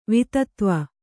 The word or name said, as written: ವಿತತ್ವ